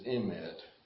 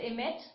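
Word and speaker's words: emet (אמת): The word in Hebrew for truth is emet. – Das Wort, hebräische Wort für Wahrheit ist emet.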